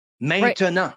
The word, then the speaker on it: maintenant: He says, the word for now in French has three syllables. Maintenant.